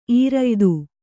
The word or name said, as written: ಈರಯ್ದು